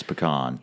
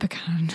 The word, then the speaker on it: pecan: Just for the record, people, it's pecan. – Yeah, I say it pecan.